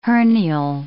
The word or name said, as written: hernial